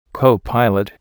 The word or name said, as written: copilot